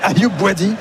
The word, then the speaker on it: Ayoub Bouadi: Je me disais c'est qui Ayoubi, je regardais ma fiche, je me dis c'est ça, j'avais besoin d'un coup de brumisateur. – Il manquait une syllabe au milieu, quoi.